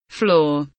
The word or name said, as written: floor